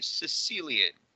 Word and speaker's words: caecilian: So the amphibian that I'm talking about is called a Sicilian. It's spelled, let me get the spelling right, C-A-E-C-I-L-I-A-N.